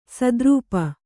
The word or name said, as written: ಸದ್ರೂಪ